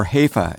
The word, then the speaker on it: Hefe: So how should people pronounce it? – Hefe. Say it with me.